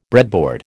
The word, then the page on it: breadboard: American English